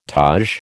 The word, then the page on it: taj: American English